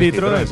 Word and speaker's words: Citroën: El 5 de febrero de 1878 nació en París André Gustave Citroën, o algo así, no sé muy bien pronunciarlo, pero vamos, es el de Citroën, el de los coches Citroën. Sí, señor.